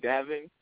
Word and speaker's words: Daven: Well, Facebook, my first name, Davin, D-A-V-E-N, last name, Faulkner, F-A-L-C-O-N-E-R.